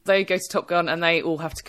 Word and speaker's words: Top Gun: It's funny that they don't pronounce it Top Gun in it. – They pronounce it Top Gun. They put the emphasis different than the rest of the world took the emphasis from.